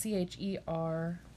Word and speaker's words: chervil: And this next plant, I'm not sure if I'm pronouncing it right, but it looks like it'd be pronounced Chervil. It's Chervil. Makes me think of DeVille.